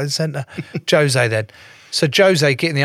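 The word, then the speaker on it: José: Is it Jose? – No, no, Portuguese. – What do you mean? – Jose. They pronounce the J's.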